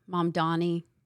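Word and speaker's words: Mamdani: The video right after Mom Donnie, first time I've ever said it correctly. – That was good. – Thank you. I've really, I in my head, it's MONDONNIE. Mom, well, Donnie.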